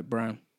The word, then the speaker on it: Braun: But I read it as Braun, but when I see documentaries and watch some things, and people refer to it, say it differently, pronounce it differently. So, Brown.